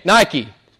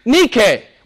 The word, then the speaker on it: Nike: Nike is how it's pronounced in the English world. It's Greek. Nike.